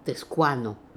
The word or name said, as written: Teskooano